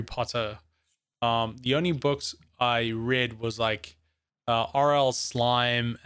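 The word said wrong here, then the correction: R.L. Stine: and all these kinds of stuff, including R.L. Stein. – R.L. Stein. See, can't even say the name right.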